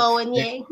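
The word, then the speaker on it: Bowen Yang: So our benches, Anna Gastar, Colin Yost, Sherry O'Terry, Horatio Sands, Boye Yang, I hope I said it right. – Boye Yang.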